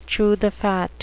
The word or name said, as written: chew the fat